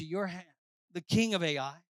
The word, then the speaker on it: Ai: and rise up and go to Ai. And some pronounce it I, okay? But I say Ai, you can say whatever you want when you're preaching.